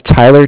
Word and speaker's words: Tyler: My name is Tyler, T-Y-L-E-R. And I'm calling from Bank of Cardiff. It's spelled C-A-R-D-I-F-F, as in Frank Frank.